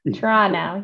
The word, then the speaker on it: Toronto: and the other one is Toronto, not Toronto, right? – Toronto. Yeah, we don't pronounce the T in, but I think that you might find that's different across Canada.